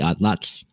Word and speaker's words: Atlatl: And when we got to or not we like when the Europeans arrived in the Americas, we called it the Atlat, which is how you say Atlatl, because that was the Nahuatl or the Aztec word for it.